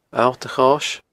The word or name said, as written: Allt a Chois